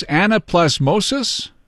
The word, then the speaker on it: anaplasmosis: Anaplasmosis? – Yep, exactly.